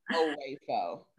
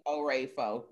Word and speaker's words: Oraefo: So the phonetics people would do O-H and then Ray, like ray of sunshine and a fold. So Amy Oraefo. – All right, y'all. You hear it now. Oraefo. Amy Oraefo.